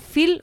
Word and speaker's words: Feel: Pues para conseguir este bono tienen que entrar en la página web Phil Capital, F-E-E-L Capital, .com, y ahí tienen que solicitar el bono Phil Futuro, F-E-E-L Futuro.